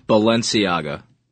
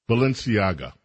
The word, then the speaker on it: Balenciaga: it's Balenciaga, I guess is the name of it. And it, thank you. We'll get one more time, please. – Balenciaga. – Balenciaga. – Correct. – Thank you. Balenciaga.